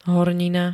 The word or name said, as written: hornina